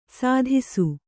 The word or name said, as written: ಸದಸ್ಸು